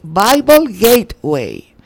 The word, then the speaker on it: Bible Gateway: Ah, pero estoy diciéndolo. Biblia Gateway. Es Bible Gateway.